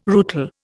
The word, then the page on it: rootle: Indian